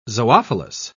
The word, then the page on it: zoophilous: British English